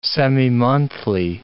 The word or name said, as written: semimonthly